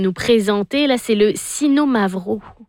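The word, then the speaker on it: Xinomavro: on dit qu'il y a un accent sur le no, donc Cino Mavro.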